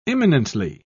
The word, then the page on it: imminently: British English